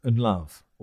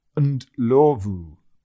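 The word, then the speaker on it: Ndlovu: And love is not pronounced and love or and love, as Ray Stubbs would. It's pronounced and lovu. And is best pronounced like and without the A.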